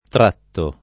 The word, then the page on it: tratto: lingua italiana